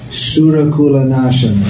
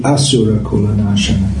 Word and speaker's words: Asurakula Nashana: It's like sometimes devotees sing Surakula Nashana. It should be Asurakula Nashana.